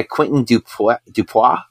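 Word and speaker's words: Quentin Dupieux: It's directed by Quentin Dupois, I think is how you say it. – Yes, that's right.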